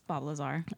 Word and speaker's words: Bob Lazar: Well, Bobby Laser. – From Vegas. I don't really know how to say his name, but. – No, it's Bob Lazar.